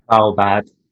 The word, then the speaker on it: Svalbard: Svalbard.